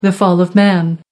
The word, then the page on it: fall of man: American English